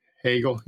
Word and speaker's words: Hegel: how do you pronounce it? Is it Hegel? Hegel? – Hegel, yeah.